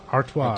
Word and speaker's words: Artois: How's that pronounced? – Artois.